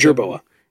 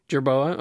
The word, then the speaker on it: jerboa: Did you guys know that Fang is a Jerboa or a Gerboa? I'm not sure how to pronounce that. And Bean is Jerboa. – Jerboa, okay. And Bean is a woodpecker.